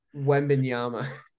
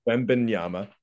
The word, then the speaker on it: Wembanyama: And you add Victor Wembanyama. I probably just butchered his name. – Wembanyama. – Wembanyama.